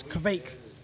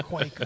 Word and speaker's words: Kvake: So think V-E-I. Think Vey, right? So V-E-I is Vey. Put a K and a V and V-K at the end. So it's Kvake. – Kvake. – Yeah, so I have to really break it down in my head to be able to say it right, because otherwise I'll just say quake or quake or quake.